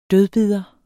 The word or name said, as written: dødbider